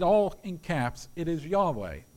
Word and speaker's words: Yahweh: This is the translation of the original name in the Hebrew, which is YHWH, we pronounce Yahweh.